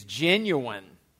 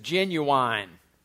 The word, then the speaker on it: genuine: The usual educated pronunciation is genuine, with the final syllable unstressed. Among some less educated speakers, genuine is commonly pronounced as genuine, with a secondary stress on the final syllable.